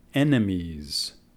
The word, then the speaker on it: enemies: Se pronuncia esa palabra al fin enemies, enemigos, enemies.